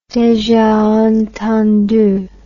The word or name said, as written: deja entendu